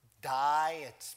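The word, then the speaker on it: die (δεῖ): The word had there is the Greek word die.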